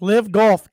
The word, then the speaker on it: LIV Golf: That's how you pronounce it. It's LIV, but it's LIV Golf, kicked off this week with a big bang.